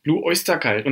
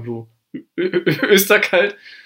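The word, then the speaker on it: Blue Öyster Cult: Blue Oyster Cult. Und da passt es sogar, weil das O wird ja mit dem Y Oi ausgesprochen. Gut, man könnte auch sagen Blue Österkalt.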